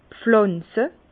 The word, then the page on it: pflànze: Bas Rhin; Herrlisheim